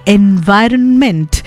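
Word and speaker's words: environment: এনভায়রনমেন্ট এখানে একটা N রয়েছে সেটা কিন্তু কখনোই সাইলেন্ট নয়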